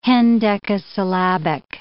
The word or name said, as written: hendecasyllabic